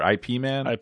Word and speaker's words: Ip Man: Is it Ip Man or IP Man? – IP Man.